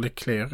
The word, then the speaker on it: Leclerc: Och den här stridsvagnen som heter Leclerc, eller Leclerc beroende på hur man uttalar det. Jag tror Leclerc är det rätta uttalet, min franska är ingen vidare.